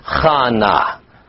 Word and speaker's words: Hannah: It's pronounced not like Hannah, but more like Hannah. You got to spit a lot. Clear your throat.